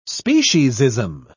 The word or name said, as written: speciesism